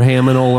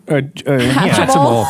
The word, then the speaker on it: Hatchimal: Or you got a harminal or haminal or whatever they're called. – Yeah. – Hatchimal.